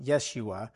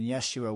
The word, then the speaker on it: Yeshua: Joshua, or as it's other, like in other parts of the Bible, it's Yeshua, or it's pronounced Yeshua, which would actually be the Hebrew form of Jesus we have in the New Testament.